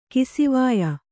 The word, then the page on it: ಕಿಸಿವಾಯ: kisivāya